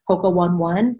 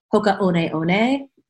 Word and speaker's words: Hoka One One: It's spelled Hoka, H-O-K-A, and then it looks like 1-1, and I always thought it was pronounced Hoka 1-1, but it's actually Hoka One One.